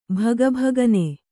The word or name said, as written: ಭಗಭಗನೆ